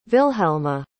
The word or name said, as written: Vilhelma